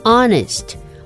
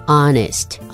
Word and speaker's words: honest: Honest Honest is spelled H-O-N-E-S-T。请注意 Honest 这个字的 H 第一个字母是不发音，所以我们念 Honest。